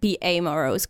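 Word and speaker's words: Beamorose: I would usually pronounce it B A Morose because there's an A in the middle, but it could all be a silent A too.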